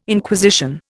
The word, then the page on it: Inquisition: Indian